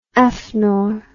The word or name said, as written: afnor